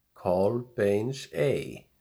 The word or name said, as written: Kolbeinsey